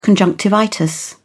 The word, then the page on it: conjunctivitis: British English